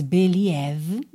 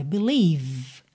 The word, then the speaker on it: believe: Se você é iniciante, atenção com essa palavra, porque ela se escreve B-E-L-I-E-V-E, mas você não diz believe, ok? Inclusive, atenção também com esse E depois da letra V, que é completamente mudo. Olha, believe, believe.